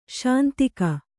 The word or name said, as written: ಶಾಂತಿಕ